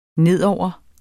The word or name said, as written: nedover